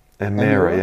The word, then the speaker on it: Emira: Emira, yeah.